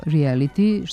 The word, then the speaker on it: reality: Englesko reality, što znači stvarnost, realnost, nije složenica i u srpskom glasi reality sa j.